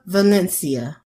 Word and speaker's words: Valencia: Angela Valencia. – Your last name is Valencia. Can you spell it? – V-A-L-E-N-C-I-A.